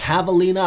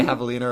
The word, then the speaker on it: Javelina: Javelina 100? – Yeah, with the silent J for both.